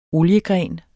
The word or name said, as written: oliegren